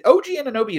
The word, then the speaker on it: Anunoby: Is it OG Anunoby? Is that how you say his name? – Yeah, you nailed it, yeah.